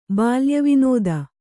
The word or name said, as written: ಬಾಲ್ಯವಿನೋದ